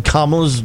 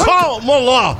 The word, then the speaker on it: Kamala: Did you see how irritated he got, Newsom, when DeSantis said Kamala's name wrong? – Yeah, it's Kamala.